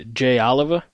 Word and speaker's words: Jay Oliva: And Jay Oliva, am I pronouncing that right? – Yeah.